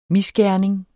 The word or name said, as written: misgerning